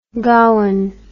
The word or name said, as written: gowon